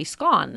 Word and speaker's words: scone: And the Brits, in looking up some history on this, 51% of the Brits pronounce that word S-C-O-N-E to rhyme with the word gone. Well, okay, that would be scone then. 42% pronounce the word to rhyme with bone or scone.